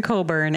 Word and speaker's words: Cockburn: C-O-C-K burn. Cockburn. – Listen, I'm not against the fact that it could potentially be Cockburn, but the people I've known, it's been Coburn. And I would say that's the safer pronunciation when you don't know somebody.